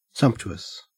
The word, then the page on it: sumptuous: Australian English